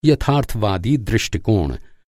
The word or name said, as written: यथार्थवादी